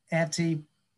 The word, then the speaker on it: Antipodean: So taking a look at these different things, so one of the things we mentioned beforehand as well was, and I'm going to get the pronunciation wrong, Antipodean. – That's correct. Yeah.